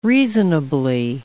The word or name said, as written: reasonably